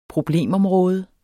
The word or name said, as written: problemområde